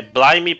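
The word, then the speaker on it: Blyme: É, Blime, pra quem não. Sempre, sempre reclamam da gente de não falar a pronúncia das coisas, é B-L-Y-M-E, né? – Sim.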